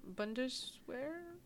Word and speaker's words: Bundeswehr: Mm, Bundeswehr? Yes, Bundeswehr? – I like it.